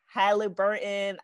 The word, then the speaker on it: Halliburton: We have Tyrese Halliburton. I feel like I never say that name correctly. Is that the right pronunciation? Okay. – Yeah, Halliburton, yeah.